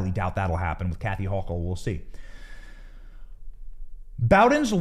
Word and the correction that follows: Boudin: loss, or Boudin, I've been saying it wrong.